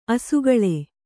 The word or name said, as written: ಅಸಿಗಲ್ಲು